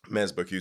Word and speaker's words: Mazbou Q: Mazbou is an anagram of my last name, Ozumba, and Q is like phonetically matching to Hugh. So like Mazbou Q is essentially my name.